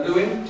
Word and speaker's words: Lewin: And conceptually, then, what we've drawn upon then is this idea from an early social psychologist called Kurt Lewin, to use a bit of kind of Americanization pronunciation of his word, of his name, sorry, who came up with this idea of the life space.